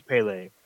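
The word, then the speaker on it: Pelé: It's Pele.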